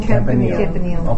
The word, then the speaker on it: Campanile: Campanile.